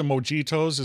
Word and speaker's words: mojito: I do like the mojitos. Is that how you pronounce it in oh, Spanish? Oh yeah, yeah mojito. Yeah. Mojito. You yes. got it. – I do enjoy the mojitos, which is mint.